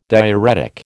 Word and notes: diuretic: American English

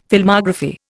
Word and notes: Filmography: Indian

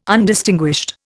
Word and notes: Undistinguished: Indian

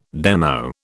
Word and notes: demo: British English

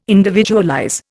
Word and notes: individualize: Indian

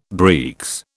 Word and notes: breeks: British English

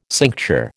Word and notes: cincture: American English

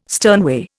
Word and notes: sternway: Indian

